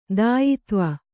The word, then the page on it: ದಾಯಿತ್ವ: dāyitva